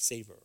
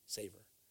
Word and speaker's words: savor: Ye are the salt of the earth, but if the salt have lost his savior, Sabor. How do you say it? Savor, right? Savor.